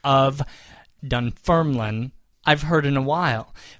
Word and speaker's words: Dunfermline: Now, he breaks it down here with asterisks and underscores and such, but it looks like Dunfermline. – Dunfermline. – Yes, emphasizing the second syllable.